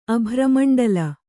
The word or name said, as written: ಅಭ್ರಮಂಡಲ